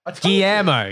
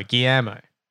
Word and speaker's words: Guillermo: It's Gamero. – Guillermo. – You. – Guillermo. – I'm sorry. – It's like Guillermo del Toro. That's how you pronounce it.